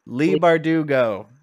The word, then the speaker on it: Leigh Bardugo: Leigh Bardugo.